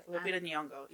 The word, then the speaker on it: Lupita Nyong'o: I thought the obvious one was Zuri, but I also was thinking Lupita Nyong'o's character. Did I say that right? – Yeah, Lupita Nyong'o.